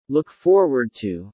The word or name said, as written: look forward to